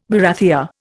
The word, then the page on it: barathea: Indian